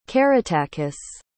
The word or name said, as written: caratacus